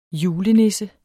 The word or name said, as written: julenisse